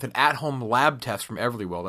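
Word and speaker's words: Everlywell: Everly Well. I actually pronounced that one right.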